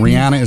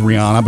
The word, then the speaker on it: Rihanna: I'm glad to hear this because there are artists whose names I pronounce wrong, and Rihanna is one of them. Everybody says Rihanna, but she's like, no, it's Rihanna.